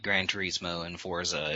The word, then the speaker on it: Forza: Forteza or? – Forza.